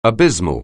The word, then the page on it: abysmal: anglais